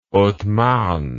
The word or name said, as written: haute marne